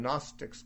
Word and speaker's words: Gnostic: The Gnostics, gnostic, or they emphasized gnosko. The word Gnostic, which starts with a silent G, means one who knows.